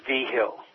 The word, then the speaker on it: Vigil: It's a Spanish name. It looks like Vigil, but it's pronounced Vigil.